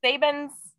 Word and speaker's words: Sabins: Sabins.